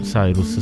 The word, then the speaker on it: Cyrus: Cyrus, sì.